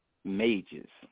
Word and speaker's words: Mages: Maggies and the gifted Mages. – Mages. – Like a mage. So mages.